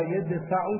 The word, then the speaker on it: يدفع: إن الله يدفع بسكون الدال مع فتح الياء والفاء مضارع دفع، والباقون بضم الياء وفتح الدال وألف بعدها وكسر الفاء مضارع دافع، ولم يحتج إلى القيد لتلفظه بالقراءتين.